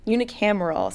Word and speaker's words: unicameral: because they have a unicameral.